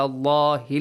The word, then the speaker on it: Allahi: Heavily pronounce the letter lam in the word Allah. Al kadhiba. Also, hamzatul wasl will be skipped. Ala Allahi al kadhiba.